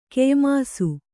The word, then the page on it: ಕೆಯ್ಮೋಸ: Kannada